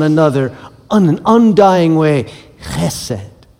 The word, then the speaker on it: chesed: It is the word chesed. Why don't you say that with me? Chesed, okay? You gotta get it in your throat. If you're not spitting, you're not saying it right.